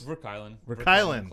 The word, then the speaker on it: VerKuilen: Did I pronounce it right? Close. – Verkuylen. – Close. Damn enough.